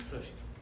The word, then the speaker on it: Sochi: In 2014, is it Sochi or Sochi, Russian Olympics? Sochi? – Sochi.